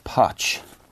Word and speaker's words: Potch: I'm going to start with the Star, and the back page headline here is Top Potch. Rhymes with notch.